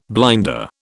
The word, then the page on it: blinder: British English